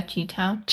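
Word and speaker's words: Chi-Town: Hashtag Cheetown, is that how you say it? – Oh yeah, Cheetown.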